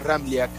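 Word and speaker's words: Ramljak: Es un poco complicado porque tiene tres consonantes juntas y la J se pronuncia como una I. Bien pronunciado, el apellido es Ramliak.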